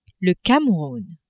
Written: Cameroun

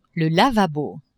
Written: lavabo

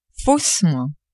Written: faussement

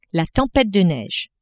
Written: tempete de neige

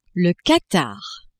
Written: Qatar

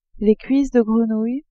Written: cuisses de grenouille

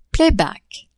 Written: playback

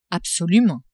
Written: absolument

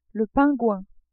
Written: pingouin

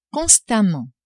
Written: constamment

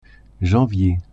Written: janvier

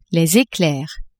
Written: eclairs

